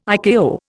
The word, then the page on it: ICAO: Indian